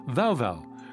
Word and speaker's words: Vaovao: Jumping from Northwest Africa to Southeast Africa, one of Hanitra Wagner's parents is from Madagascar, where she has now formed Vau Vau, a duo that is spelt V-A-O-V-A-O, which means new in Madagascan. As a fan of Dada, she appreciates the double meaning. The band's name is pronounced Vau Vau, which is the sound a barking dog makes in German.